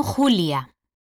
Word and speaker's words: Julia: Me llamo Julia. – ¿Cómo se escribe? – Se escribe J-U-L-I-A.